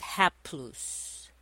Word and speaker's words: haplous: That Greek word is haplos. And that's spelled H-A-P-L-O-U-S.